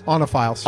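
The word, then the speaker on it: oenophiles: Is it Wenophiles? – Onophiles. – Onophiles? – Onophiles. – I never knew how to pronounce that.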